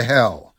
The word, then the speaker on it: Hel: Flixbus has restored Poland's controversial Route 666, connecting Krakow to the Baltic coastal town of Hel via the Highway to Hel. And by the way, the town of Hel is spelt with just one L, but it's pronounced the same way.